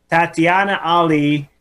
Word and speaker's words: Tatiana Ali: Tatiana Ali.